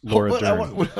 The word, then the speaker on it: Laura Dern: Lord Dern, Jurassic Park's Lord Dern is in it. Playing, what'd you say? – Jurassic Park's Laura Dern.